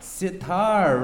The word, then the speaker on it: sitar: Sitar, right? – Very good. – You know what? It rhymes with guitar, right? Because actually, tar means string in Arabic. So this is a sitar.